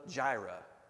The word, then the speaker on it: Jireh: Jehovah Jireh, or I've heard it's actually pronounced Yireh.